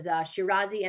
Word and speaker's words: Shirazi: There's Shirazi and Mashani. Did I say that correctly? – Yes.